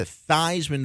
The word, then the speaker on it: Theisman: I think it used to be Theisman. – I think it was Theisman, and then he went ahead and changed it to Theisman to rhyme with Heisman as he tried to win the freaking Heisman.